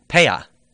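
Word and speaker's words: Peah: Paya Paya. – Yeah. – So Paya uh, literally means corner.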